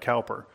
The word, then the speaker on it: Cowper: Now, if you look up his name, it's spelled C-O-W-P-E-R. It looks like Cowper, but it's Cowper, pronounced Cowper.